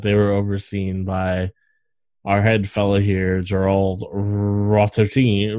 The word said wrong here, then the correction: Potterton: Rotterton, Potterton. Holy shit! yeah, you gotta say it right.